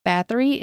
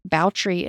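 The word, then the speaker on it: Báthory: Elizabeth's last name, Bathory, is actually pronounced Bautry in Hungary.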